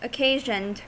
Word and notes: occasioned: American English

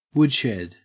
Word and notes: woodshed: Australian English

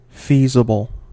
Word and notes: feasible: American English

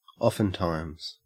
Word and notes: oftentimes: Australian English

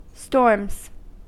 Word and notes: storms: American English

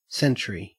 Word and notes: century: Australian English

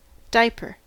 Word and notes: diaper: American English

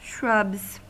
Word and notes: shrubs: American English

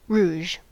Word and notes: rouge: American English

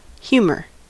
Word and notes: humour: American English

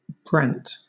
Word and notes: brant: British English

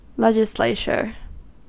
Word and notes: legislature: American English

